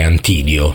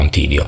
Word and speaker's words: Antidio: perché se no un santo che si chiama Antidio, forse sarebbe stato, come dire, un refuso non indifferente, quindi direi che l'accento è Antidio. Sì, facciamo Antidio.